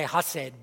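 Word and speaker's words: chesed (חסד): The Hebrew word that is translated loving kindness is spelled C-H-E-S-E-D. And the C in Hebrew is silent, and the H has kind of a K guttural sound. And I'm not Hebrew or Jewish, so I don't know if I should even try to pronounce it, but it's chesed. Some people say hased, but there's more of a chesed.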